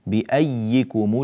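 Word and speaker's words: بأييكم: الآية السادسة بأيكم المفتون كما ترونها، كلمة بأيكم بارك الله فيكم يا آن مكتوبتان في المصحف، إلا أننا ننطق بواحدة مشددة، فالياء الأولى أدغمت في الياء الثانية وشددت الياء الثانية على أي حال، فصارت بأيكم المفتون بأي، فالنطق بياء مشددة هي الياء الثانية، والياء الأولى أدغمت في الثانية، فهنا ياء زائدة في الكتابة.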